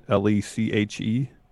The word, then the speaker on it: Leche: Uh, the Bilkman uh, Leche. Is how you pronounce it? L E C H E? – Leche, yeah.